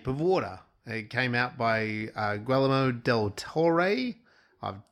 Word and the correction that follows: del Toro: del Toro.